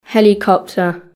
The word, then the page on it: helicopter: British English